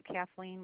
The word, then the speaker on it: Kathleen: My name is Kathleen with a K. – Could you spell that for me? – K-A-T-H-L-E-E-N.